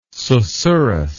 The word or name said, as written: susurrus